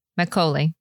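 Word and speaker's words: makgeolli: And then premium, what did you pronounce it? Majolia? Magagoli? – Macaulay. I had the pronunciation up here. Macaulay. Yeah. – Macaulay. – I think essentially the G is silent.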